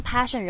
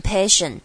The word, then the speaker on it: passion: 好，finding your passion thing，这个地方 passion 容易会忽略掉，可能念成 passion，但是它这边是一个 apple 的 a passion， passion thing is that。